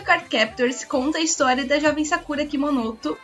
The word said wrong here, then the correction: Kinomoto: Eu nunca sei se é assim que se pronuncia. Kinomoto, o, o nome dela.